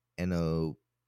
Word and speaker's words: anosognosia: No, A-N-O-S-O-G-N-O-S-I-A. Anosognosia. That's not even close. – Okay, I'm going to look up the pronunciation. Anosognosia. – Anosognosia. – Oh, not even close.